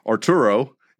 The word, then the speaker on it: Arturo: So my legal first name and all the Hispanics that are hearing this, you could be like, no, Holmes, that's not how you say it. It's Arturo.